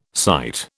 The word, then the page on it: cyte: British English